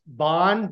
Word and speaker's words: Bahn: But now, from your emailing back and forth with you, kind of your sign says CEO of product, is it pronounced Bahn, BAHN? – yep.